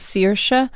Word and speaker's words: Saoirse: The character she played, her name is Saoirse Ronan. – Saoirse. – But actually, the Irish way to pronounce it is Saoirse.